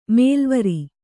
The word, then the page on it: ಮೇಲೆವರ್: kannada